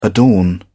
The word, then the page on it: adorn: British English